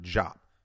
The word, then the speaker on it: Diop: And I need to do a presentation on Dr. Diop and Shiganta Jop. It's pronounced Jop,